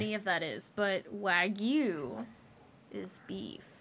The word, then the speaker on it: wagyu: How do you say it again? – Sushi better introduce me to Aquaman shit. – Ragu? – Wagyu. – Wagyu is a red sauce.